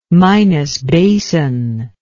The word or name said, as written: minas basin